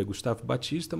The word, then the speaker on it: Baptista: Esse P é mudo, é Gustavo Batista, mas tem o P.